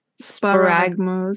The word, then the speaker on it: sparagmos: Sparagmos.